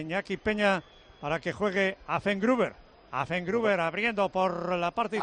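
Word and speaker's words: Affengruber: ¿Estás diciendo Effengruber? Es Affenbruger.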